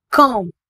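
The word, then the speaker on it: calm: For example காம் சிஏஎல்எம் இதனை காம் என உச்சரிக்க வேண்டும், எல் சைலண்ட்.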